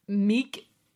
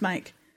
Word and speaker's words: Meik: It's spelled M-E-I-K. – M-E-I-K. M-E-I-K. – And it's pronounced Mike.